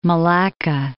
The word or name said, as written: Malacca, Strait of